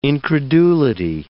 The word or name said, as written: incredulity